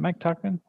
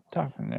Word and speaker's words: Tauchman: Mike Tauchman was supposed to be, and I'm saying that right? Mike Tauchman? Tauchman, yeah? – Yeah.